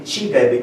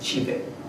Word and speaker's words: csibe: And this is the so-called classical Young-Baxter equation, or CYBE, or I learned from Zsuzsi to pronounce it Csibe because it's chicken in Hungarian. So I pronounce it Csibe.